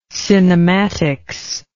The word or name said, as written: cinematics